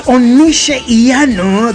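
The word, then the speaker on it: Onise Iyanu: Yes, it's Onushe Iyanu. That's the pronunciation there.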